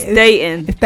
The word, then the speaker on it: Datin: It's Datin. – Yo, mate. It's like a silent T. – Is it Datin, mate? Yo, you right, mate? – Datin.